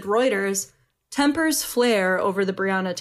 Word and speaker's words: Reuters: It's Reuters? Reuters? – Reuters. Yep, you got okay.